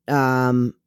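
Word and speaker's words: Shakman: Matt Sackman, right? – Yep. – Okay, that's how you say his name.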